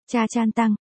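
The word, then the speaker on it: cha chan tăng (茶餐廳): Ở Hong Kong có một thể loại tiệm ăn gọi là cha can tinh, đọc là cha chan tăng, hay cha can tinh phục vụ ăn sáng, ăn trưa và trà cà phê.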